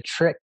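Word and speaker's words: TRIC: Well, we call our approach the trick to shared rural prosperity. And Erica, trick is spelled differently than how your listeners may hear it. It's T-R-I-C.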